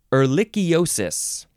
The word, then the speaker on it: ehrlichiosis: This one is Ehrlichiosis.